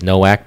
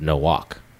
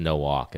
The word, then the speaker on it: Nowak: But everyone says Nowak, but actually my parents and family would pronounce it Nowak. – Oh. – So kind of like Bafo. That's how you pronounce Bafo. – So technically it's Nowak. It's German. Yeah.